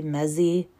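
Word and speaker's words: Mezey: So they bring in Jillian Mezzi. I think I'm saying that right. I'll spell it. It's M-E-Z-E-Y, Mezzi.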